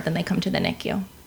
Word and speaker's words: NICU: Topics today include uh, what it what it's like to work, particularly in the in the NICU.